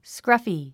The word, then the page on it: scruffy: skrʌ’fi; スクラフィ